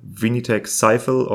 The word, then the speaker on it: Vinitech Sifel: It is spelled V-I-N-I-T-E-C-H-S-I-F-E-L. Vinitech Sifel, or how you pronounce it in français, Vinitech Sifel.